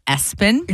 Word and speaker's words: ESPN: It's spelled ESPN, but it's pronounced Espen.